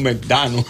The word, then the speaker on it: McDonald's: que los McDonald's en inglés son Medano. Sí, es Medano, la pronunciación de McDonald's en inglés es Medano.